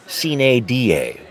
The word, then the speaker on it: sine die: Well, a lot of cheering there, and if you're wondering, folks, the traditional Latin pronunciation would be sine die, without a day, signaling the end of the session, but we'll let them say it however they want to.